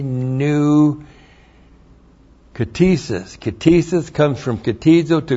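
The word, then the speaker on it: ktizo: K-T-I-S-I-S. Now, katesis is the noun that comes from the verb katizo. Looks like that. K-T-I-Z, long O.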